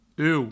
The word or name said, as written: uou